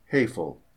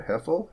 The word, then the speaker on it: Hefel: Lila Hefel, I'm going to spell that, H-E-F-E-L, Lila Hefel, Hefel, Holy Cross, Iowa.